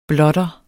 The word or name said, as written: blotter